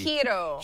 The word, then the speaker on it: giro: Hiro. – G-I-R-O.